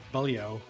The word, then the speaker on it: Baliao: So I always say by low or something like that. But by, how do you pronounce it again? – Baliao.